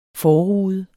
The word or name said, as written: forrude